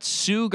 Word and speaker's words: Jsu: I looked up two different things, and one of them said Jackson State University. Like, and that's not referring to him. That was seriously a pronunciation thing that I saw. And then the other one was JSU Garcia. And it's like, nope. – Oh, okay. – Both wrong.